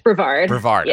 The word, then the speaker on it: Brevard: Brevard.